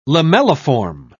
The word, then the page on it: lamelliform: British English